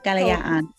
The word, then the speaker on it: Kalayaan: Help me pronounce this. – Sure. Kalayaan. Kalayaan.